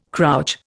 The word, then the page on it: crouch: Indian